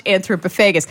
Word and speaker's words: Anthropophagus: It's got like an extra syllable in there that I tend to leave out. Anthropophagus? – Because it's pop and then haggis.